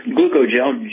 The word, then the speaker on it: glucogel: Yeah, it's glucogel, G-L-U-C-O-G-E-L. And it's a blend of things that are required by your cartilage, ligaments, tendons, connective tissue, and disc to actually maintain themselves. Okay, glucogel,